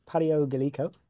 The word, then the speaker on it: Pario Gallico: Today I've come back to the Ancient Technology Centre in Framborne, and my guest is Caroline Nicolay, otherwise known as Pario Gallico.